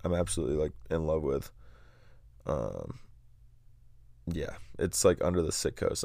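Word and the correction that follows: Citgo: sign, the Sitgo sign.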